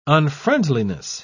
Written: unfriendliness

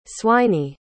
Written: swiney

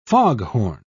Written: foghorn

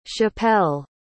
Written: chappell

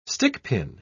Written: stickpin

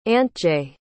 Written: antje

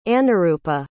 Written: anuroopa

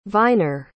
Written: viner

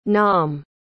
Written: nom

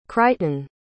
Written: crichton

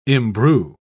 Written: imbrue

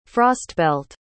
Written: frostbelt

